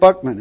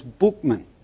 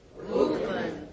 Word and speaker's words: Buchman: First thing when I started interviewing people in the Oxford, in Moral Rearmament, I said, so what about Frank Buckman? And they said, no, his name's Buchman. So if you're going to talk about him, say his name right. So what's his name? – Buchman.